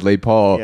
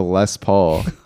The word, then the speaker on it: Les Paul: Well, it wasn't a joke because he said late Paul. – Yeah. – But he was serious about it. – But this guitar is called a Les Paul. – Oh, Les Paul. – But it's spelled L-E-S.